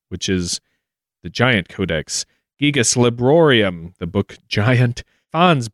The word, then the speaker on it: Gigas: Pronunciations vary. You can say Gigas, Gigas, Gigas, Gigas. But we're going to go with the Greek origin, which uses the letter gamma for hard G's, pronouncing it Gigas.